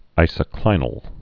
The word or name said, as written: isoclinic